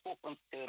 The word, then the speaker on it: хохломская: И правильнее, конечно, произношение хохломская роспись. Ну, сейчас уже как-то все уже привыкли, но я вот для того, чтобы просто знали.